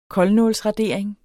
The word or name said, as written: koldnålsradering